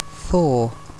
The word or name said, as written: thaw